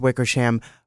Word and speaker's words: Wickersham: Seth Wickersham, that's how you pronounce his last name, correct? – Yes, sir.